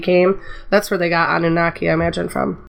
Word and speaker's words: Anunnaki: If any of you have played, and I'm going to ruin this name, Anukakai Genesis? – Anunnaki? – Yeah, Anunnaki. – Anunnaki. Why has nobody ever pronounced that in my presence like that?